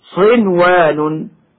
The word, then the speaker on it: صنوان: وكما ننطق بالنون الساكنة مظهرة قبل الواو من كلمة أيضا هكذا: صنوان وغير صنوان يسقى بماء واحد، صنوان.